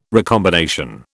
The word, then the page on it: recombination: British English